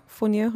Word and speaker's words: fonio: Now, one thing you sent over that I've never had before is fonio. Fonio, am I saying it correctly? – Yes, fonio.